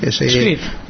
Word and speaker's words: Squid: Y esto, yo haría mucho copy de utilizando un proxy, como puede ser Squid, no sé si lo estoy pronunciando bien, pero bueno, se lee así, Squid. – U, I, latina y D.